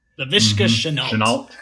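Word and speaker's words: Laviska Shenault: LaVisca Chenault.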